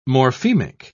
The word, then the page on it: morphemic: British English